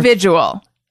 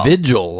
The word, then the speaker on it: vigil: This is a visual. I mispronounced visual once, and Jeff got it, and he plays it periodically. This is a visual. – Vigil, like with candles.